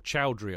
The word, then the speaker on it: Chaudhry: Anyway, next emails from the absolutely lovely Akshay Chaudhry, or Chaudhry, I suppose, would be a better pronunciation, who, of course, we've known for a long time.